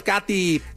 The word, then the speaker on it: Cathy: Katy se escribe C-A-T-H-Y, Katy C. de la Barca, y en todas las plataformas me pueden encontrar. Nos vemos la próxima semana, chao. – Gracias, Katy, gracias de verdad.